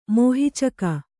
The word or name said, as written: ಮೋಹಿಚಕ